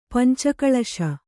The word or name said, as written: ಪಂಚಕಳಶ